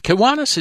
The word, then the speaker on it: Kiwanis: And Kiwanis is spelt a bit like the start of a Kiwi bird, but then it changes. It's K-I-W-A-N-I-S, Kiwanis Marabin.